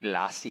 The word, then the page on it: glossy: American English